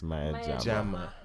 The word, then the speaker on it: Maya Jama: Okay, it's Maya Jama. – Jama. – Like Jamal, Maya Jama. No L.